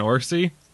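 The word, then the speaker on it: Orci: This movie is written by, Kurtzman and Orsi.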